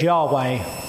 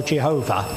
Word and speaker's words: Yahweh: Yahweh or Jehovah. Jehovah reflects the German influence, of course, with the J. But Yahweh is actually the pronunciation, the best pronunciation you get from YHWH with vowels in.